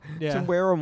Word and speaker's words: Temuera: between Temuera, is that how you say his name? Yeah. Temuera Morrison and Jason Momoa.